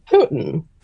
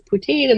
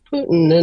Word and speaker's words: poutine: Well, when we went, our server told us it was actually pronounced Putin. – Putin. – Yes. And we were like, oh, because she said the word and we were like, oh, wait, how do you pronounce it? And that's what she said. And we're like, oh, we thought it was poutine. And they're like, no, it's Putin.